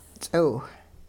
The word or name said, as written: ts'iw